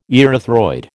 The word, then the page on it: erythroid: American English